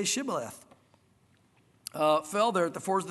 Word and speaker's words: Shibboleth: He said, say Shibboleth, and they would say Sibboleth. And so he'd be like, ah, you know, let me get a second try or something. I don't know. For he could not pronounce it right.